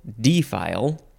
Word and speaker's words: defile: And you can also emphasize the first syllable, defile.